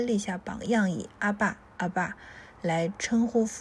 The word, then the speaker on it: 阿爸: 阿爸，阿爸，是效法亚兰文读音的希腊字，是犹太人称呼父亲的一个习惯。